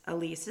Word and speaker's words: Ilise: Welcome to Wide Open Spaces with Elise. Elise is spelled I-L-I-S-E.